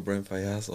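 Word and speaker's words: Brent Faiyaz: Man kann den auch Brent Fayas aussprechen.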